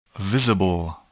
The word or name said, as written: visible